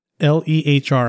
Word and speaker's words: Lehr: L E H R. How would you say that? – L E. Yeah, Lehr.